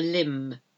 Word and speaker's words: limb: To go out on a limb, L-I-M-B, silent B.